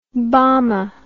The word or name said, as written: bama